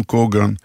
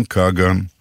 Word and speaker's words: קגן: ברוסית, במקום האות ה' מבטאים ג', וכך במקום כהן, עולי רוסיה מבטאים קוגן, במקום כהן, הם מבטאים קגן.